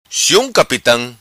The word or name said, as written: Sium Capitam